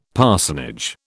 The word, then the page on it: parsonage: British English